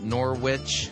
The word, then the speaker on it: Norwich: And he did send me an email to let me know that when I was talking about Julian of Norwich, I was pronouncing it wrong. It's not witch like in sandwich, it's just like another R. It's Norwich.